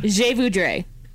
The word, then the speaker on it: je voudrais: Je voudrais.